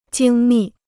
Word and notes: 精密: jīng mì

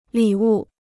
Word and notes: 礼物: lǐ wù

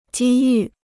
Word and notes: 机遇: jī yù

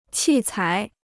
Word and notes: 器材: qì cái

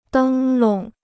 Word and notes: 灯笼: dēng lóng

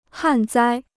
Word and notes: 旱灾: hàn zāi

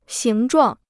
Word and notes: 形状: xíng zhuàng

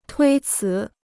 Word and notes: 推辞: tuī cí

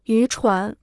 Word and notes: 渔船: yú chuán

